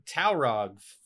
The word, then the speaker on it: Taurog: Best Director, Norman Taurog. For Skippy. Did I say his name right? – Yeah.